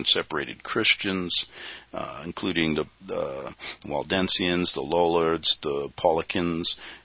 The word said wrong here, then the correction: Paulicians: That sounds like Pelican, but it's Paulicians. It's P-A, you know, sometimes I can't pronounce all this stuff. So what? P-A-U-L-I-C-I-A-N-S. It gives the history of the English Bible from John Wycliffe to William Tyndale.